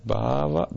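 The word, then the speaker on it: bhava (भाव): How do we say bhava? Bhava means mood, with long A in the beginning, and then bhavita. Is it correct? – Yeah.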